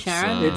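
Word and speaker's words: Charon: I'm never sure how it's pronounced. Is it Sharon? Because that feels wrong. It's definitely no, it's, Sharon. Uh, it's, 400% Sharon.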